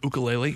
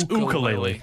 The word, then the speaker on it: ukulele: I think you can learn to play the ukulele. You, you said it wrong, by the way. It's, yeah, it's ukulele.